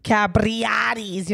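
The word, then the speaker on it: Capriotti's: And am I saying that right? – Capriati's. – Capriati. That's what I said, right? – No, but we keep going. – Did I forget the pre? – You forgot a lot of things. – Might be true. Capriotis. How do you say it? – Capriotis. – Capriotis. You want me to roll it? – There you go.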